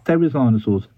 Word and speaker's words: therizinosaurs: These therizinosaurs with a theta, therizinosaurs, they were secondarily evolved to be herbivores.